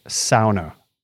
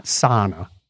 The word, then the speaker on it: sauna: The rest of the world pronounces it sauna. So I am going with the Finnish, not sauna. That's an American bad way of pronouncing it.